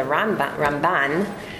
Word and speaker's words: Ramban: So in case you've ever heard of Nachmanides, and that's called the Ramban, so the way that you can hear the difference is Rambam has the accent on the first part of his name, and the Ramban has the accent on the second part of his name. So because they sound so similar, the accents are different, so you'll be able to recognize it when you hear it.